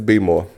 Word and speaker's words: Baltimore: Some of us enunciate Baltimore way more than it should, but it's be more for some people.